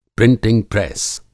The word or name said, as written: प्रिंटिंग प्रेस